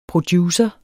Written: producer

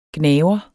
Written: gnaver

